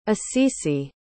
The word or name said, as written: assisi